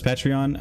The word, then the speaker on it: Patreon: Oh, I don't really know how to say it. Patreon? – Yeah, that's what I thought it was, Patreon.